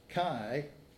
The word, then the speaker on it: chi: Well, that word chiastic, by the way, or chiasm, it comes from a Greek letter pronounced chi, but it looks like an X.